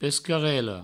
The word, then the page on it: pescarela: Provençal; pescarello